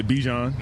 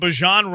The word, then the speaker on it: Bijan: lot of people say Bijan.